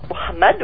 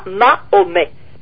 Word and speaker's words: Mohamed: Donc, il préfère que vous prononciez comme ça avec un mot, Mohamed ou Mohamed, si vous voulez, peu importe, mais pas Mahomet.